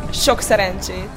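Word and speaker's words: sok szerencsét: The good luck is szokszerencsé. – That's how you say it? – Yes.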